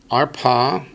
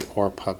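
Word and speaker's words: Orpah: Arpa. Varut. It's actually Orpa, but they didn't put the vowel in there because they thought it'd confuse you.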